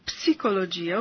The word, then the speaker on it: psicologia: Well, in Italy, we say psicologia, of course, because that's how it's spelled.